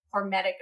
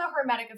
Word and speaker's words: hormetic: And so there are cases where with intermittent fasting, because of the hormetic effect, I said it properly, there we go. Because of the hormetic effect, sometimes you can see actually an improvement with intermittent fasting in their car.